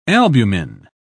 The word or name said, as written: albumin